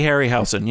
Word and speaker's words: Harryhausen: It wasn't all the, how do you say his name? Ray Harryhausen? Is that how you pronounce it? – Yeah, Ray Harryhausen, yeah.